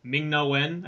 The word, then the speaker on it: Ming-Na Wen: I like the actress Ming-Na Wen.